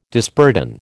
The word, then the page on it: disburden: American English